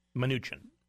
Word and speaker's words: Mnuchin: Mnuchin.